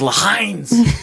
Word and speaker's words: Heinz: Everybody's been saying it wrong. It's Heinz.